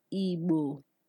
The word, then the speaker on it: Igbo: An example of a word with B is Igbo.